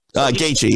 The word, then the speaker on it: Gaethje: So this whole idea, and I'm going to say his name wrong, but Gaethje, Justin Gaethje. – Gaethje, yep.